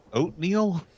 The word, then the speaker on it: Otniel: Oatmeal? – Yes, that's exactly right. Is it Oatmeal? So his name's like Neil? – It's O-T-N-I-E-L. Oatmeal? – O-T-N-I-E-L.